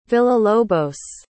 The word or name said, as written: villalobos